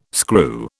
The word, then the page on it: screw: British English